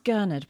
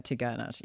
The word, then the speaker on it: Gurnard: I thought it was pronounced Gurnard, but I'm learning how to say it like a local.